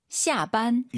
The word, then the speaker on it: 下班: 힘을 좀 빼고 하시는 게 좋을 것 같아요. 너무 쌍비읍이 강하면 좀 별로예요. – 要更自然一点. – 해볼게요. – 下班. – 이거 이거. 어? 지금 쌍비읍 아닌가요? 어, 비슷해요. 근데 완전 우리가 알고 있는 반 이건 아니에요. 비읍과 쌍비읍의 중간, 시합반 이게 좋을 것 같습니다.